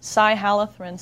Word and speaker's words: cyhalothrin: Lambda, like the Greek letter. Cyhalothrin, C-Y-H-A-L-O-T-H-R-I-N. That's pretty effective, but it's only available to applicators, certified professional applicators.